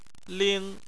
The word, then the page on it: ling: 1